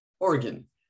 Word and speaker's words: Oregon: See, it's Oregon. I made sure I said that right.